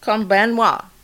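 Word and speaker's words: Konbanwa (こんばんは): Konbanwa. I'm practicing my Japanese. – All right. I love it. – So, good evening. – So hey, what is it? – Konbanwa.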